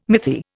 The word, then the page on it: mythi: Indian